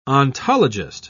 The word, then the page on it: ontologist: British English